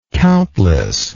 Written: countlessly

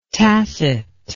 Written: tasset

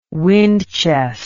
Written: windchest

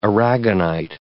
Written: aragonite

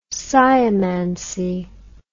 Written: sciomancy

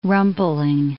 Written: rumpling

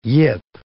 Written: yip

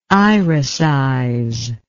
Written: iricize

